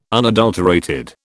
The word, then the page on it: unadulterated: British English